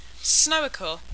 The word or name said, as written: snowickle